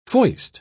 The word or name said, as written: foist